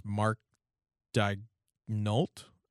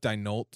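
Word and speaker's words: Daigneault: I believe it's Mark Dignault. I think the G is silent.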